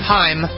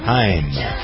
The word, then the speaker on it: Haim: Is it Haim? Is that how you pronounce it? – Haim. – Haim.